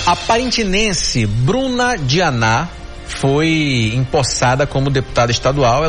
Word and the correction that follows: Brena Dianá: Aliás, Brena Dianá, né? Pronunciei errado aqui.